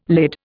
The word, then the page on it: lid: Indian